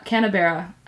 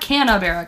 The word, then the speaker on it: Canberra: Also, Canberra. I'm pretty sure I'm saying that right. Not Canberra.